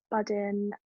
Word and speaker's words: Budden: And I was listening to the Joe Budden, have I said that right? Podcast. – Yeah.